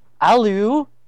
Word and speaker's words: aluminium: For example, Americans pronounce aluminum aluminum, and Brits pronounce it aluminium, and leisure is leisure American style and les sure Brit style.